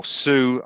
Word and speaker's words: Soult: And he then is, his aim is to push Soult, or Soult, I should say, to give him his proper French pronunciation,